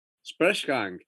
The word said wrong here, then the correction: Sprechgesang: How do you say? Sprechgesang. Yeah, yeah. – Sprechgesang. Schwieriges Wort.